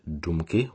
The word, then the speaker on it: Dumky: While the first one, Punky, is well understood all over the world, the second part, Dumky, which in Czech rhymes with Punky, needs explanation and can be roughly translated as melancholy or, alternatively, the kind of sad music that can be quite adventurous.